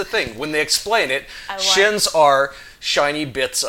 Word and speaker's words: shins: Okay, so the currency in the Ninth World is called S-H-I-N-S. Most people pronounce it shins. When I started reading it, I started pronouncing it shines. So I may say either word, don't fault me for that.